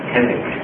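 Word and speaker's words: Keswick: 英国的 Kendick，那个 W 是不发音的，念 Kendick 就是说人不要做什么，完全让主做最重要是完全的投降，不要做，让主来为我做，让我就得胜嘛这一派。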